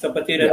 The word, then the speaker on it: Kapatiran: I'm not sure if I'm pronouncing it right. – Yes, Kapatiran yeah. Party.